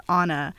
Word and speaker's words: Ana: then some, there would be a few good moments where I'm like, no, it's Ana.